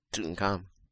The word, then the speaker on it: Tutankham: Tutankham. Tutankham? – Tutankham.